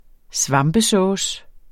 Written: svampesauce

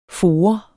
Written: forer

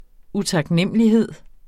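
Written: utaknemlighed

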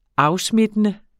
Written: afsmittende

